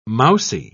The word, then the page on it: mousey: British English